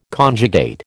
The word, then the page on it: conjugate: American English